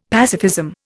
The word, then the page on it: pacifism: Indian